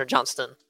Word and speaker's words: Johnston: Is it Johnson or Johnston? – It's Johnston. – Johnston.